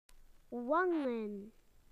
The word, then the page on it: cheeks: British English